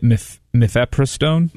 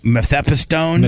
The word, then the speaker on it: mifepristone: How do you say that? – I would say it mifepristone. – Mifepristone? – Mifepristone.